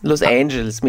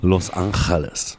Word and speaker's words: Los Angeles: Los Angeles mit Ash spricht man es aus, nicht A. – Los Angeles.